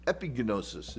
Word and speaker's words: epignosis: So this is epignosis, is how you say that in Greek.